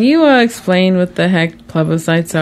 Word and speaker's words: plebiscites: We heard the term plebiscites. Am I saying that right? – Yep.